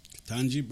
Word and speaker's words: Ketanji: Ketanji, yeah, Ketanji.